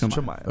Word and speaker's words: Chimaev: Yeah, you're the one actually pronouncing it wrong. You fucking weirdo. – I thought you said it's Chimaev. – No, I said Chimaev. – Oh, it's Chimaev. – Yeah, it's Chimaev.